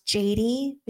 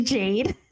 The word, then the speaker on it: Jayd: Yeah, well, everyone can find me through Studio Conceal and or just through my name, Jade Hernandez. I know a lot of people think my name is pronounced Jady, which is cute, but it's actually Jade.